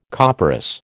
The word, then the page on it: copperas: American English